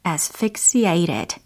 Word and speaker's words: asphyxiated: 철자부터 알려드리면 A S P H Y X I A T E D 과거 분사형으로 활용이 되었습니다. 발음을 해보자면 asphyxiated, asphyxiated 라고 하시면 되는 거고요.